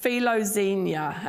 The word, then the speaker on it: philoxenia: And it's pronounced philoxenia.